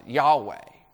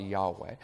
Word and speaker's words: Yahweh: That's probably how we should pronounce it. Yahweh. The consonants and the vowels is probably Yahweh.